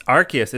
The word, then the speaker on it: Arceus: It's Arceus. It's a hard C.